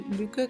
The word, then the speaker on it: Lykke: así que pueden buscar el Huga o el luque que se escribe L-Y-K-K-E, eh, the, little, Book of Luke.